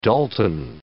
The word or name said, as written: Dalton